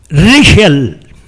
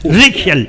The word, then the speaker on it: Riechel: Richel. Richel. R-I-E-C-H-E-L. Richel.